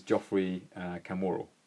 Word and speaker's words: Geoffrey Kamworor: And his name is, to pronounce it correctly, as it should be in Kenyan, is Geoffrey Kamworor.